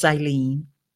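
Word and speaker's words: Ileane: So just real quickly, in case you're listening to this and not seeing it, Ilene is spelled Ilene. And I might as well get that all out there for us as we're just getting to know one another, that I do have an unusual spelling of my name, but it is pronounced Ilene.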